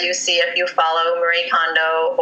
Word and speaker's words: Kondo: And I, when I was listening to an interview with Marie Kondo, is that how I say her name, Marie Kondo? – Yeah.